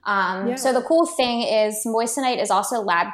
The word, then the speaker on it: moissanite: Moissanite? – Moissanite, yes.